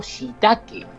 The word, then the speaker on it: shiitake: Es el famoso shiitake, el shiitake, ¿eh? que muchos de nosotros conocemos. Se escribe S-H-I-I-T-A-K-E, el shiitake.